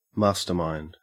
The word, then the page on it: mastermind: Australian English